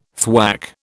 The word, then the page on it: thwack: British English